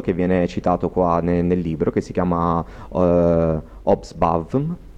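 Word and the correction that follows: Hobsbawm: che l'ho letto malissimo probabilmente, ma non so assolutamente di cosa sto leggendo. Poi Gine ve lo pronuncia Osbam. – precisamente. Ah, Osbam, era facile, bastava chiederlo a Gine.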